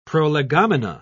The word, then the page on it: prolegomena: British English